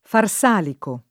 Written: farsalico

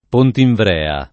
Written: Pontinvrea

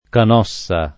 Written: Canossa

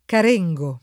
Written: Carengo

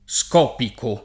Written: scopico

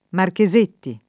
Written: Marchesetti

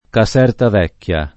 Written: Casertavecchia

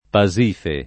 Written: Pasifae

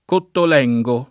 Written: Cottolengo

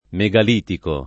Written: megalitico

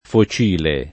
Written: focile

